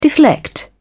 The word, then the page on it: deflect: British English